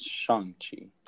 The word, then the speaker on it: Shang-Chi: I think it's Shang-Chi. – Did I not say Shang? – I think you had a bit of an emphasis on the A when you said Shang.